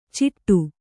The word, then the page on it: ಚಿಟ್ಟು: ciṭṭu